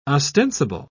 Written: ostensible

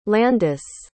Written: landis

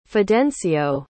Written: fidencio

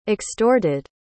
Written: extorted

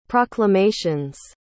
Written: proclamations

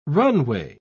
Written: runway

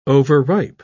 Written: overripe

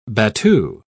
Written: battue

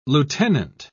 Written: lieutenant

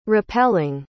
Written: rappelling

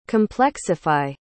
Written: complexify

